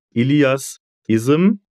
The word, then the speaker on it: Ilias Ism: Today my guest is Ilias Ism, if I'm pronouncing that correctly, I hope.